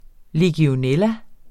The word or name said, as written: legionella